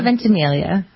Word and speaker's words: Ventimiglia: Ventimiglia.